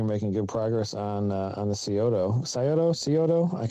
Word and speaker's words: Scioto: It's Scioto. – Scioto.